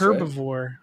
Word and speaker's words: herbivore: Is it not pronounced herbivore? herbivore? – No, it's herbivore. herbivore. It's it's silent. – Oh, it's not herb. No, but herbivore.